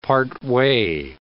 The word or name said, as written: partway